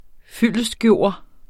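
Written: FYLDESTGØRES

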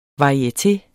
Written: varieteers

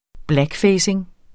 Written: blackfacing

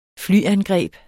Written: flyangreb

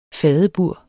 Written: fadebur

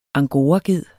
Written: angoraged

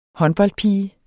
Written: håndboldpige

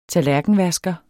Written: tallerkenvasker

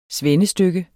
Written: svendestykke

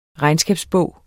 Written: REGNSKABSBOG

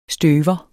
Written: støver

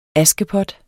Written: askepot

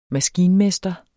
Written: maskinmester

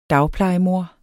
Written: dagplejemor

